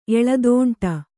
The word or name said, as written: ಎಲೆದೋಂಟ